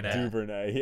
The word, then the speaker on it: Duvernay: Duvernay, yeah.